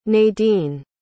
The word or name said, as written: nadine